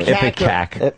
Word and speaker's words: ipecac: How do you say that word? I-P-E-C-A-C? I can never say that word. – Ipecac. – All right, Ipecac.